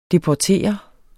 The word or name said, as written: deportere